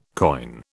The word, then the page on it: coign: British English